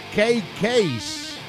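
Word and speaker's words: KK's Priest: A ver, deberíamos pronunciarlo, si pensásemos en inglés, como K-Case, ¿vale?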